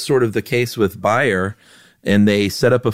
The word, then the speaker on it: Bayer: If you heard me say buyer and you're thinking, dude, it's Bayer aspirin. What are you, German? No, I'm not German, but that's right. How you pronounce it over there. It's Bayer. We pronounce it Bayer over here.